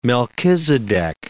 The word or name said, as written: Melchizedek